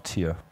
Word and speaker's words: tier: Spricht man das Deutsch aus? – Nein, spricht man Englisch aus, aber es heißt dann halt immer noch Tier.